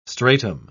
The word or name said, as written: stratum